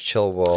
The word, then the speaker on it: Childwall: Yeah, Childwall. – But it's pronounced Chilwall.